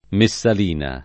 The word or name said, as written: Messalina